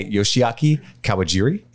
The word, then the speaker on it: Yoshiaki Kawajiri: But it was written and directed by Yoshiaki Kawajiri. And I'm sure I said that wrong. – Nailed it.